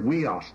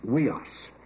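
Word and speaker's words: weos (υἱός): It's the Greek word weos, not technon. That's spelled U-I-O-S. We put an H before it when we transliterate, make it weos.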